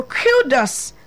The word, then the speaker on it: kudos: Kudos. That's the right pronunciation.